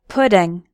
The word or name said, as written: pudding